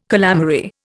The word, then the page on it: calamary: Indian